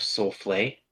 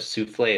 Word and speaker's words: soufflé: Chef Soufflé in Luigi's Mansion. – I don't remember. – Can you pronounce that correctly? Instead of souffle, it's soufflé.